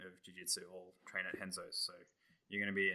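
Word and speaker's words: Renzo's: It's pronounced Henzo's.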